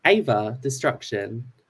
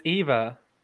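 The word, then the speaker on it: Eva Destruction: Are you calling her Ava? – Ava Destruction. – It's Eva. Like Eve of Destruction.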